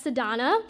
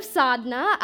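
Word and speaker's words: Sadhana: So, on the first day of school, of kindergarten, when the teacher called roll and pronounced my name as Sadhna, instead of Sadhna, I raised my hand and was like, yes, I'm present, and I didn't think to correct her.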